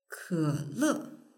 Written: kele

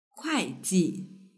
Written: kuaiji

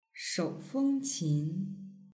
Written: shoufengqin

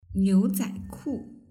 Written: niuzaiku

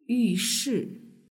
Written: yushi

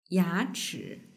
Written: yachi

